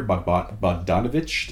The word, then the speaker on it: Bogdanovich: It's Peter Bogdanovich.